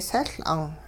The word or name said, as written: sehL'ung